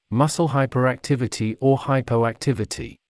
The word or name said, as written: muscle hyperactivity or hypoactivity